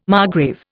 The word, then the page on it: margrave: Indian